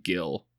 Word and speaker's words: Gil: We'll pretend he's a cousin and we'll call him Gil. Where did Gil come from? – Rhymes with Will.